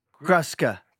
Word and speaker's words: Gruska: That U.S. in the middle of my name is pronounced us. – Gruska.